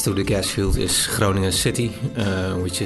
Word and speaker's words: Groningen: But can you tell us a bit about what the area around, and I'm going to try and pronounce this properly, Groningen? – Groningen. – Groningen, okay. Well, I mean, what's the area like?